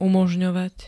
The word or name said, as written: umožňovať